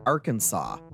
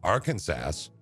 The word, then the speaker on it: Arkansas: It is illegal to mispronounce the word Arkansas. – Arkansas? Really? – Or Arkansas. Yeah, you probably won't get arrested for it, but they will not be happy. They could arrest you if you were in Arkansas and you called it Arkansas.